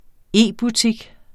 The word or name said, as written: e-butik